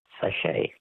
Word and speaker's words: sachet: Sachet. – A sachet. S-A-C-H-E-T.